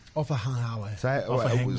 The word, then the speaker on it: Ofahengaue: So offa is just O-F-A. – Yep. Mm-hmm. – And then you go hang. Hang. Where you let the G roll off your tongue. – Hang. – Ofahengaue. – Ofahengaue. – Ofahengaue. That's not off your tongue. That's more your throat. – So do you... – off the top of your roof of your mouth.